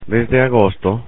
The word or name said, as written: desde agosto